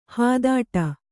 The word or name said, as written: ಹಿದೌಠ